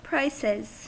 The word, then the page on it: prices: American English